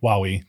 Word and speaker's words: Huawei: Huawei, thank you.